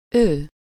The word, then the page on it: ő: /ˈøː/